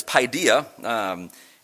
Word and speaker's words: paideia: In the Greek word, I actually had to listen to a YouTube to know how to pronounce it, but it's paideia in this section, and it refers to the rearing and education of the ideal member of society.